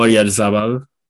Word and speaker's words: Oyarzabal: Oh, Oyarzabal.